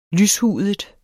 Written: lyshudet

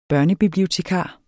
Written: børnebibliotekar

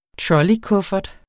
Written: trolleykuffert

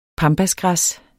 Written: pampasgræs

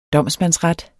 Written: domsmandsret

